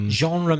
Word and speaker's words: genre: And genre means type, yeah? – Yeah. – How do you spell that? – It's a French word. G-E-N-R-E, genre.